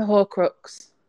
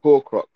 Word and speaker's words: Horcrux: The Horcrux. – Horcrux, yeah.